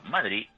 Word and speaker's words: Madrid: Y por cierto, como veáis, como pronunciamos los de Madrid, Madrid es sin ningún tipo de consonante final. Acentuamos la I y es como se nota al que es verdaderamente de Madrid.